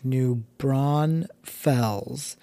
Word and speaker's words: New Braunfels: I was in New Braunfels, and it's a small town uh, in between Austin and San Antonio, and it's New Braunfels. I know people that live in New Braunfels and for whatever reason put an S in the middle and pronounce it New Braunfels, and it is like nails on a chalkboard with somebody tapping a pen while waiting on you.